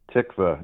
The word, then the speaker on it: Tikva: So let's start with what a Tikva fellow is. Am I pronouncing that correctly? – Tikva. Yes, yes, you are, actually.